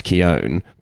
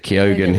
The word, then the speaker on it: Keoghan: But also I've discovered that I believe, based on my research, that his name should be pronounced Keown, but he pronounces it Keoghan himself.